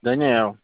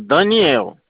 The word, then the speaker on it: Daniel: Daniel. – Eu consigo falar com o Geriel nesse telefone? – Não, Geriel não, Daniel.